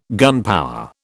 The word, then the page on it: gunpower: British English